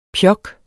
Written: pjok

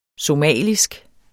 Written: somalisk

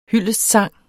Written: hyldestsang

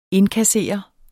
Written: indkassere

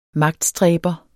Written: magtstræber

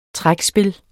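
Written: trækspil

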